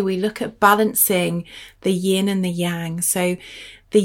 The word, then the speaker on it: yin: So something that, the way that I remember it is yin rhymes with dim.